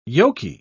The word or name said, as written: yolky